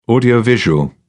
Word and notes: audio visual: British English

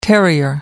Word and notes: terrier: American English

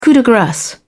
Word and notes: coup de grace: British English

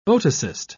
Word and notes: otocyst: British English

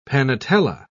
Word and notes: panatela: British English

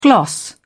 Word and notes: gloss: British English

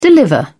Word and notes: deliver: British English

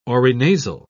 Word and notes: orinasal: British English

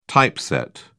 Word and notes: typeset: British English

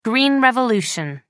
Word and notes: green revolution: British English

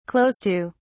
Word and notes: close to: British English

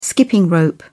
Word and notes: skipping-rope: British English

